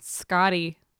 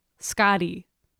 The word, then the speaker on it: Skadi: It's Scotty. Pronounce it right. – Like your name's Scott? – No, S-K-A-D-I, Scotty.